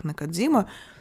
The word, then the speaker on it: Накадзима: Да, и я в курсе, что в русскоязычной традиции транскрипции японского языка фамилия этой писательницы звучит как Накадзима, но мне удобнее произносить ее на японский манер.